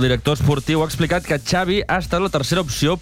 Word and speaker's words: Xavi: Xavi Hernández. – Xavi. Okay. – Yeah, Xavi like chocolate.